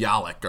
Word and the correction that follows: Yaklich: or something last week. So, Yaklich. That's a very good coach,